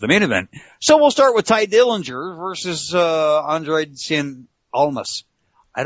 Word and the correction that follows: Andrade Cien Almas: It is Andrade Cien Almas.